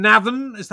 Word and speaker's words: Navan: Talking of Ireland, you come from Navan. Am I saying that right? – Yeah.